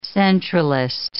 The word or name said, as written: centralist